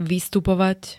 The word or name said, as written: vystupovať